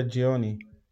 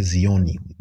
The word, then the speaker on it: jioni: Habari ya jioni. – Habari ya jioni. I was going to say zioni. It's G, but it's spelled with the Z. – No, J-I-O-N-I.